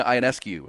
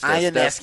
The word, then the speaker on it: Ionescu: New York Liberty guard Sabrina Ionescu against Ionescu. Thank you. I think I was mispronouncing it.